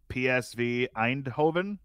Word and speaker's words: Eindhoven: I don't know how to pronounce this team, but PSV Eindhoven. – Ooh. – Does that sound right in the Champions League? – Sounds great.